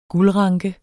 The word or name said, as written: guldranke